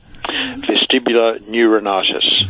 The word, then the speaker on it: vestibular neuritis: Vestibular neuritis.